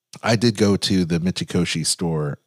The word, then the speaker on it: Mitsukoshi: Yes, Mitsukoshi.